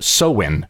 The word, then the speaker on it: Samhain: But it goes all the way back to the first century, 2,000 years ago, when the Celtics celebrated a holiday called Samhain. It's if you looked at the word, you wouldn't get Samhain out of it, but that's how it's pronounced.